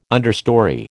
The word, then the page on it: understorey: American English